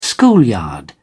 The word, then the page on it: schoolyard: British English